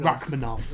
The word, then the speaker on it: Rakhmonov: I'm thinking, yes, Shavkat Rakhmonov. – Rakhmonov. – Oh yeah, Shavkat, yeah. See, I've got you there. Yeah? I've got the pronunciation.